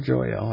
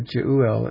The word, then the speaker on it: Jeuel: Jeuel, or Joel, I guess we could maybe say, but Jeuel, it looks like it's pronounced there, it's separated in two vowels,